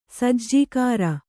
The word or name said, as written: ಸಾಜಗಾರ